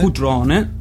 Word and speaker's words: Crotone: Lui è un artista calabrese di Crotone, nato a Cutrone in Calabria. – Questa è la pronuncia propriamente calabrese.